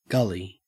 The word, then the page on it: gully: Australian English